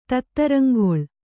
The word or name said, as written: ತತ್ತರಂಗೊಳ್